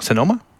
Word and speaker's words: Sonoma: And everything seemed to be like, check out what, I'm going to say it wrong, Sonoma? Is that it? – Yeah.